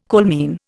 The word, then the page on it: coalmine: Indian